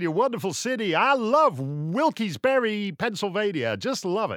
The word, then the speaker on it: Wilkes-Barre: We're going to be in Reading, Pennsylvania, and Rochester, New York, and Syracuse, New York, and then Wilkes-Barre. I think that's how you pronounce it. Pennsylvania, because it's like it's spelt like Barry, Vermont, but it's not said like Barry, Vermont.